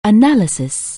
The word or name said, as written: analyze